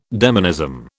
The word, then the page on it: demonism: British English